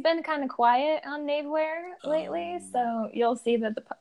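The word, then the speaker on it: Knavewear: It's Navewear. It's Knave. – Ah, yes. – Wear. – The K is silent, so I'm glad.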